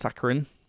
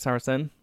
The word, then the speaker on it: Saracen: That's how he pronounces Saracen. I'm, um, i I keep thinking it's Saccharin, but it's not. It's Saracen.